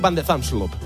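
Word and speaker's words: van de Zandschulp: Jodar juega a las 6 de la mañana contra Botic Fan de Zansrubup. ¿Lo he dicho bien? Bueno, parecido. Bueno. En neerlandés, Botic Van de Zansrubup.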